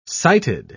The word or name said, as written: sighted